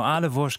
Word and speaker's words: Ahle Wurst: Eine wichtige Rolle spielt bei dir die Ahle Wurst. Habe ich das richtig ausgesprochen? – Ja, genau. Ahle Wurst, genau.